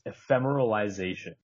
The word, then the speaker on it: ephemeralization: So the way you pronounce it is ephemeralization.